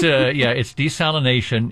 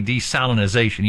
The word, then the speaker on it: desalinization: It's desalination. It's one of those types of things that, you know, it doesn't sound right, desalination. So you say desalinization. You got to add that extra syllable.